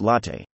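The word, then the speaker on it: LoTTE: We introduce LOT, pronounced LATTE, a new dataset for low-end GTALT OPIC stratified evaluation for IR.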